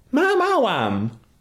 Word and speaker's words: Maoam: Mauam. – It's Mauam. – Girl, M-A-O-A-M. Mauam. There's a whole song about it, how you say it.